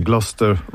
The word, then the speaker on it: Gloucester: Jest napisane Gloucester, ale czytamy Gloucester Road.